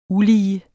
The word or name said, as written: ulige